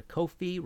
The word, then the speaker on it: Ko-fi: If you like the show and want to support future episodes, please go to Ko-fi, rhymes with no fee.